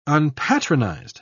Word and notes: unpatronized: British English

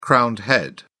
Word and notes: crowned head: British English

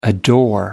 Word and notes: adore: American English

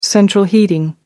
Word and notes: central heating: American English

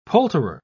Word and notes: poulterer: British English

